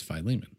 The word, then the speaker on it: Philemon: What's the official pronunciation? – The accepted English pronunciation is Philemon.